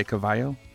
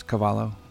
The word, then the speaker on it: Cavallo: I got your name right, didn't I? Cavallo? – Yeah, you actually pronounced it correctly. I mean, a lot of people say Cavallo, but no, it's Cavallo.